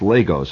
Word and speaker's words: Lagos: And by the way, it's pronounced Lagos, and not Lagos.